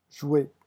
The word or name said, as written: jouer